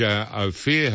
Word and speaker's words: Ophir: Orange Ophir Hotel is pronounced Ophir. So now I've got Ophir, Ophir, and Ophir.